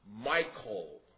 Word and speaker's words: Michal: And I might say this is Saul's daughter, and I've looked in the pronunciation guides and found at least two ways to pronounce it. I'm going to pronounce it, if I pronounce it the way I intend, Michal.